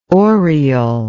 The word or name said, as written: oriel